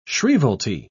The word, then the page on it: shrievalty: British English